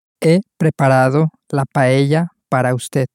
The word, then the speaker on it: paella: Again, the double L at the end of paella makes this same y sound. So in Spanish, if you do come across any double L's, you can know that they are pronounced y and not l.